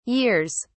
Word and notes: years: English; British English

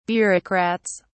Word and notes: bureaucrats: British English

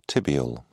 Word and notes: tibial: British English

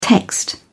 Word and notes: text: British English

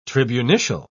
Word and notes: tribunitial: British English

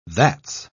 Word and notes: that's: British English

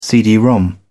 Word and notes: cd-rom: British English

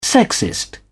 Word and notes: sexist: British English

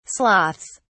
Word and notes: sloths: British English

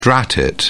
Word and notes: drat: British English

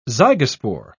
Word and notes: zygospore: British English

